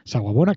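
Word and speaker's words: Sawubona: Y recordemos esa guabona, que esa guabona nos recuerda lo más importante, es el saludo zulú que dice Saguabona, que se escribe S-A-W-U-B-O-N, agua. Saguabona.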